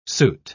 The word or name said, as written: soot